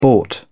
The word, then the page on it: bought: British English